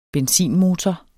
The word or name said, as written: benzinmotor